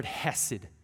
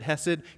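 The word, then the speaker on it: hesed (חסד): My Hebrew professor used to say, if you're not spitting on people when you say the word hesed, you're not saying it right.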